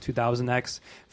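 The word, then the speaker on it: Year 200X: So it looks like 200X, but it's pronounced 2000X.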